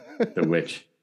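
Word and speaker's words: The VVitch: The Witch.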